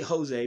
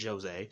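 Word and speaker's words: José: The thing with Jose Aldo, and I say Jose because that's how it's pronounced, it's not Jose, but the thing with Jose Aldo is Jose Aldo was one of those guys who dominated the game for years.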